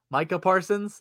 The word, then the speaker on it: Micah Parsons: Yeah, and the Cowboys have Micah Parsons. Did I say it right? – It only took you 3 weeks.